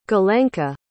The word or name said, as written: galenka